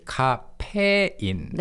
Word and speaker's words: 카페인: And what's interesting is in English, the pronunciation is caffeine, but in Korean, we say 카페인.